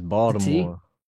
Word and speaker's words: Baltimore: As most listeners will know, the author of Ghosts of East and soon to be West Baltimore. Baltimore native, Baltimore blooded through and through. – That T is killing my ears, bro. It's Baltimore.